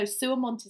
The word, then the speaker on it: Suermont: Of course, calling her Sue, but she said, "Oh no, Suamont is the company name." – Oh, Suamont. – How do you spell that? – It's Suermont Knitwear.